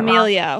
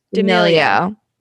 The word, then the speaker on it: D'Amelio: Okay, so the DeMayo sisters, my sister's gonna kill me for probably pronouncing that D'Amelio. – Wrong. D'Amelio.